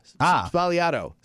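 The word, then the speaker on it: Sbagliato: I don't think you pronounce the G, though. This says it's supposed to be pronounced Negroni Spagliato. Spagliato. Spagliato, that's how it's pronounced.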